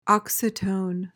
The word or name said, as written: oxytone